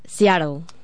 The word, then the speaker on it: Seattle: Es Seattle.